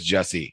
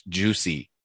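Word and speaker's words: Jussie: First of all, I thought it was Jesse, but apparently not only did his mom raise an idiot child, but she didn't have spell either, because it is apparently pronounced Juicy.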